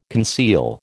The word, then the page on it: conceal: American English